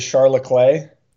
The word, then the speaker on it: Charles Leclerc: Also, is that how you pronounce Charles Leclerc? Is Charles Leclerc? – Yes. Close.